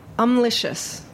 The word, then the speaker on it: Umlicious: Also joining us today is Eric Rhee, who has a company called, I may not pronounce this entirely correctly, Umlicious. – That's correct.